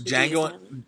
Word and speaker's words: Django: So Django, Django is the D is silent.